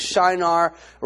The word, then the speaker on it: Shinar: Now, I want to say something to you, because Shinar rhymes with dinar, dinar.